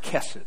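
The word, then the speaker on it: kesed (חסד): It's the Hebrew word kesed, or hesed. It looks like hesed, but it's pronounced kesed.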